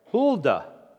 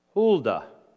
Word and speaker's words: Huldah: I'm hoping I'm pronouncing her name right. Huldah. Huldah. I'll spell it for you. It's H-U-L-D-A-H. Huldah.